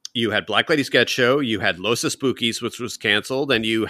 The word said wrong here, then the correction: Los Espookies: Come on, say it properly. – Los Espookies. – There you go.